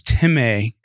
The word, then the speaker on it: timē: It looks like if you're just reading it, it's spelled like time. But the word is timē, and it means to value, respect, have high esteem, to treat as precious, weighty, or valuable.